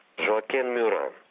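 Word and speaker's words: Joachim Murat: Жоакен Мюран, так по-французски произносится это имя.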